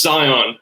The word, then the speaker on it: Cyan: And I hope I said that right. It's Cyan, right? Richard, if you can bring Cyan into the show. Cyan, I've said it like five times now, so I really hope I said it right. – You're good. It's Cyan. I tell people.